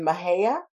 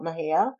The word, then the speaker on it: Mexia: In fact, we have a little area not too far from Houston in Mejia. It's spelled Mexia, M-E-X-I-A. It's called Mejia.